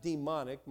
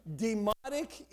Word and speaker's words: Demotic: It had Greek, it had Demotic Egyptian, not demonic. My wife said, make sure you pronounce that correctly. Demotic Egyptian, and also hieroglyphic picture writing.